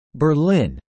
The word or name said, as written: Berlin